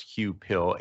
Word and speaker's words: Huw Pill: There's a story out that the chief economist, I believe his name is pronounced Hugh Pill, H-U-W-P-I-L-L, the chief economist from the Bank of England, came out with some statements that raised a lot of eyebrows in the UK.